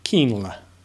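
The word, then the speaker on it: Kienle: Sebastian, I always say Kilner. I say Kiln, and it's supposed to be Keenler. Keenler. Sebastian Keenley.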